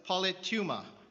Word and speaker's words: politeuma: The Greek for citizenship, or conversation, as it's translated in the King James Version, is politeuma. Politeuma. I'll spell it. P-O-L-I-T-E-U-M-A.